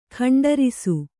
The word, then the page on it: ಖಂಡರಿಸು: kannada; khaṇḍarisu